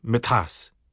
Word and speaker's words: metas: Examples are wachusk, muskrat, which would rhyme with the tusk, and metas, pants, which should rhyme with a pass.